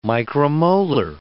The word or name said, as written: micromolar